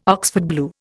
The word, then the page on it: Oxford blue: Indian